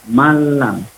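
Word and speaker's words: malam (مَن لَّمْ): Jangan kita baca man lam, tapi malam. Atau malam. Min robbihim, kalau tulisannya min robbihim.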